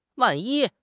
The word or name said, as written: wan4 yi1